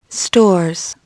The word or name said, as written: stores